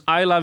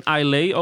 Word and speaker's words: Islay: Is dus een Isla whisky, geen Islay overigens. Veel mensen spreken tijd als Islay, omdat je het zo schrijft.